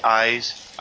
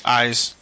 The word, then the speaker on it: Ys: I never know how to pronounce that series. – I believe it's called. I had trouble too, but I believe they wanted to be Eyes. – Eyes.